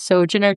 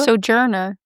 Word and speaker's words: Sojourner: I think it's Sojourner Truth. – Sojourner.